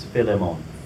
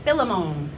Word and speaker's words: Philemon: Just like Philemon, I can never say that right. – Most people don't. The actual way to say that is Philemon. – Philemon.